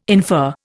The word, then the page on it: infer: Indian